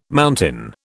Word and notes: mountain: British English

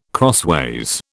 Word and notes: crossways: British English

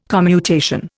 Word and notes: commutation: Indian

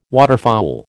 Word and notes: waterfowl: American English

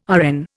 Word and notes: RN: Indian

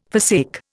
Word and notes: forsake: Indian

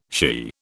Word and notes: she: British English

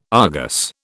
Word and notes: Argus: British English

